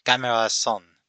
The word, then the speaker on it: Gamera 3: Or, actually, I should say it right like this Gamera San, Jashin Kakusei, are also known as Gamera 3 Revenge of Iris, and is directed by Shusuke Kaneko.